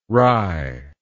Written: rai

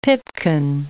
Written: pipkin

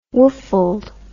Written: woofled

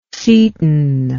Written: cetin

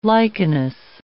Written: lichenous

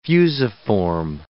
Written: fusiform

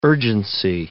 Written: urgency

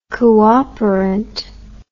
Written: cooperant

